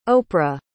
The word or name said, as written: oprah